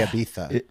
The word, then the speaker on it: Ibiza: I think you have to say Ibiza. – It's an Ibiza. – Ibiza. – Yeah. – So you pronounce the Z like a TH.